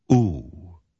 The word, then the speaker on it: ooh: Ooh. Say it. Ooh. Yes, it rhymes with blue and two and you. Say those words. Blue. Two. You. Okay, now say the word good.